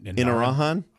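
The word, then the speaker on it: Inarajan: Can't even pronounce it. – What? – Irajan. – Irajan? – In Arahan? – Yeah, there you go.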